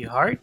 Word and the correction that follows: Heard: como se llama el apellido? Siempre lo pronuncio mal. – Amber, Hart. – Heard. Eso mismo.